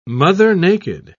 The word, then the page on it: mother-naked: British English